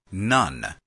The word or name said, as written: none